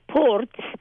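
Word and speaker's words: Porț: It's called Ports. In Romanian, it's spelled P-O-R-T with a little apostrophe under the T, which makes the pronunciation port. In Hungarian, it's spelled P-O-R-C, because the C in Hungarian is a sound of T.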